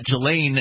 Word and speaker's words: Ghislaine: Guffrey said she was instructed by Epstein's alleged fixer, socialite Ghislaine is how it's pronounced, Maxwell, to, quote, do for Andrew what I do for Jeffrey.